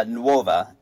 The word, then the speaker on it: Nuova: Nuova, N-U-O-V-A, which is new.